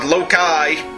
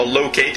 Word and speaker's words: Loki: The evil trickster god Loki, or Loki, depending on your pronunciation, arranges the villains of the Marvel Universe into tackling foes who are unknown to them in the ultimate acts of vengeance.